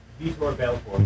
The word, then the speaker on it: Vitor Belfort: Victor Belfort. I may be mispronouncing that. I don't follow boxing. But say that again. – Vitor Belfort. – Vitor Belfort. Thank you.